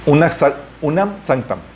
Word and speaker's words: Unam Sanctam: De hecho, Bonifacio VIII, eh, en el siglo XIV, eh, en su bula, hay una bula que se llama Unam Sanctum. unam Sanctam, perdón.